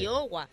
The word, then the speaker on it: Iowa: ¿Esto cómo se dice? – Iowa.